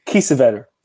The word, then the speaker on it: Kiesewetter: His name is Jerome Kaiswetter. Is that how you say it? – Kiesewetter. Oh, of course. It's German.